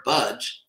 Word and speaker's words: bhaj: The verb budge, as in the English word, I won't budge, that's actually how it's pronounced.